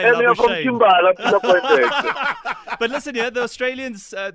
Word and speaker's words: Labuschagne: Labuschagne.